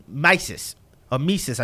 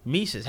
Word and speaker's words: Mises: Hayek is, knowing, you know, who Milton Friedman was, knowing, you know, about, Mises, I should pronounce his name properly, Mises, having read Sowell's writings, having read Walter Williams' writings, having read these things in order to have an understanding.